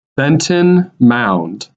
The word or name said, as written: Benton Mound